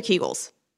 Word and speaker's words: Kegels: Is it Kegels or Kegels? – I think you can say either. I think that there's no, I've heard it both ways.